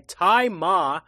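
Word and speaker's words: Tzi Ma: And Consul Han, who is played by Tai Ma, which I had to find out the pronunciation of that because it's spelled T-Z-I.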